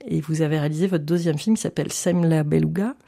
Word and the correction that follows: Sem la Belugue: je le dis bien. – Sem la Belugue. – Voilà, c'est ça.